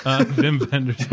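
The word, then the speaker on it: Wim Wenders: Vim Vendors movies. – I was going to say, it's pronounced Wings of Desire. – Yes, that's true. – Vimmy V? – All W's are V's now.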